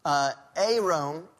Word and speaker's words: arum: And this happens with the word Arum, is how you pronounce it, A-R-U-M.